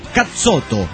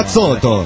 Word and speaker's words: cazzotto: Dice Bafangulo, Cazzotto, pirata, se pronuncia Cazzotto. He aprendido. – Cazzotto.